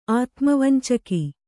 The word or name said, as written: ಆತುಮವಂಚಕ